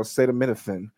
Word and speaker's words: acetaminophen: broke that down acetaminophen. – Acetaminophen. – it is. It no, no, I, I didn't doubt it, you. You you know the word. You pronounced it correctly.